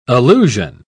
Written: allusion

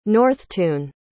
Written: Northtun

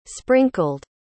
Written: sprinkled

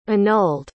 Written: annulled

